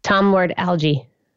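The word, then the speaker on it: Tom Lord-Alge: And so first question is, do you pronounce it Tom Lord Alge or Tom Lord Alge? – Tom Lord Alge. – All right. That's how I do it too.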